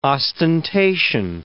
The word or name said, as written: ostentation